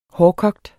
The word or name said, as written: hårdkogt